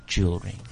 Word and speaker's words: jewelry: Jewelry. Jewelry. – Jewelry. Say. – Jewelry. – Jewelry. – Jewelry. – Jewelry. Jewelry. – Again, we've got an extra vowel here that we don't need to pronounce.